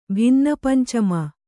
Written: ಭಿನ್ನಪಂಚಮ

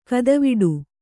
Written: ಗತಿವಡೆ

